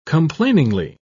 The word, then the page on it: complainingly: British English